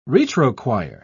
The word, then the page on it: retrochoir: British English